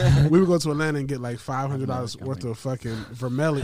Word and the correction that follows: vermeil: How do you say three. – It? – Vermeil. – That sounds right.